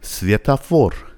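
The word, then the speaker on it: سویتافور (светофор): باز دوباره دوتا او داریم، او دوم فشار کلمه روشه، فور فور. برای همین اونجا او تلفظ میشه ولی او قبل از نقطه فشار آ تلفظ میشه. سویتا فور.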